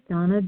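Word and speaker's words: Dana: My name is Donna B.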